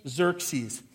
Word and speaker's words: Xerxes: But all those X's together, it's King Xerxes.